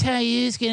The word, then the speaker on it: ayahuasca: Actually, man, it's pronounced ayahuasca, it's Peruvian, and you got to him.